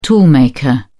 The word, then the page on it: toolmaker: British English